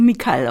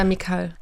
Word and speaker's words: Mikael: Ja, also Mikael auf der zweiten Silbe betont. – Ja, Mikael.